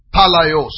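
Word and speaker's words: palai (πάλαι): Is the word palaios. I'll spell it for you. P-A-L-A-I-O-S. P-A-L-A-I-O-S. It's taken from another word. P-A-L-A-I. P-A-L-A-I. P-A-L-A-I, palai.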